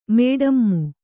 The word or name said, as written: ಮಡಿಮೆ